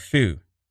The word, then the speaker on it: Feux: The new single titled Fall Again, featuring it's spelled F-E-U-X. It is French. I believe it's pronounced. It's not faux. It's fou.